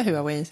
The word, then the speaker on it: Huawei: Ja, vi har varit och pratat lite med Huawei. – Mm. – Eller Huawei. De säger själva Huawei, så jag får försöka lära mig. – Ja, vi har ju lärt oss att det egentligen ska uttalas Huawei.